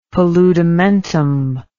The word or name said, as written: paludamentum